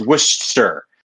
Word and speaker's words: Worcestershire: A lot of people would say Worcestershire, but it's just, for some reason, you silence a middle, you silence the middle. – A whole chunk of the word. – syllable. Yeah. So like, you say Worcestershire, right? The Stershire part of the word is not Stershire. It's they just make it a stir. It's Worcester. So, well, I have learned something today.